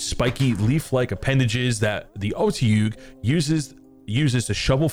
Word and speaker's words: Otyugh: It's called the Atyug. O-T-Y-U-G-H. The Atyug.